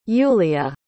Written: Yulia

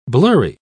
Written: blurry

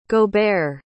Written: gaubert

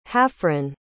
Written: hafryn

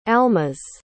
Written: almas

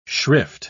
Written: shrift

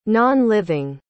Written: non-living

